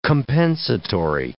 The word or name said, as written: compensatory